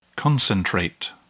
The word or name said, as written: concentrate